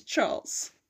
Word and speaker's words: Charles: It's Charles. If you ask me, it's Charles. That's the most logical way, right? That's the French way to say it, so I'm guessing that's how you say it. That's how you pronounce it.